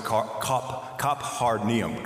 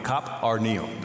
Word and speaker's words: Capernaum: The locals pronounce it Kaphar-neum. Kaphar-neum. That's how they pronounce it. I still call it Capernaum because that's what my VBS teachers taught me, right?